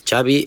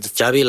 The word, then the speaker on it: Xavi: I want to start with asking you, what is the correct pronunciation of your first and last name? – Xavi Hernandez. – Xavi, okay. – Yeah. – So I heard it. – Xavi like chocolate.